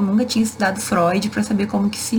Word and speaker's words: Freud: Que é um psicanalista muito conhecido, e eu li Freud, porque a escrita se escreve Freud, mas a gente fala Freud, porque é alemão.